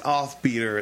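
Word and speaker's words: Offbeatr: It's Offbeater. It's spelled like it sounds, but without the E at the end of Beater. – B-E-A-T-R.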